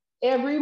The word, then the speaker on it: Every: But the campaign name is TN, which is our initiative of our state, the number 4, Every, my last name, I'm going to spell it for y'all, E-V-E-R-Y, phonetic for my military folks, Echo Victor Echo Romeo Yankee, the number 1.